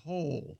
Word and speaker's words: Hole: Ole. – But it's pronounced Hole. – Yeah. – Hole? – Detective A. Hole. Yeah. – It's spelled H-O-L-E. Detective Hole.